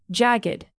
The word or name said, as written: jagged